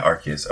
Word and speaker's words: Arceus: And then the other decks we've got, we have an Arceus, and they came out with the correct pronunciation recently, didn't they? Like the official one? Is it Arceus? – I think it's with a hard C, yeah. – Yeah, Arceus.